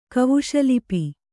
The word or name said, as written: ಕವುಷಲಿಪಿ